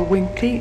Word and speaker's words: Winkley: Mr. Chairman, members of the panel, my name is Barbara Winkley. That's spelled with a W-I-N-K-L-E-Y. – W-L-I-N-K-L-E-Y. – W-I-N-K-L-E-Y.